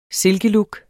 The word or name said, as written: silkelook